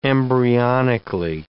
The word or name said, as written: embryonically